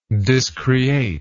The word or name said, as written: discreation